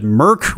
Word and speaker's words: mIRC: We pronounced it Merc, which has its own sense of irony.